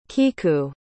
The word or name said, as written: kiku